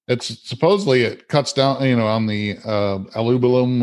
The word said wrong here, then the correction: albumin: or whatever what is that? – Albumin. – It's approaching. Albumin. I can't pronounce it. Albumin. There we go.